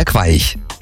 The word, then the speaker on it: Queich: Ja, habe ich das richtig ausgesprochen? An der Queich. – Genau, ja.